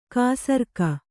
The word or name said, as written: ಖಾಸರೋಗ